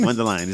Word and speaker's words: Mundelein: Mundelein.